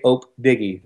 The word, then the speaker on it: OpDiggy: Yes, I perform mostly nowadays under the name of DJ Ope Diggy. That's spelled O-P-D-I-G-G-Y.